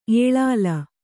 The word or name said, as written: ಏಳಿಲ